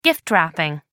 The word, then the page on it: gift wrapping: American English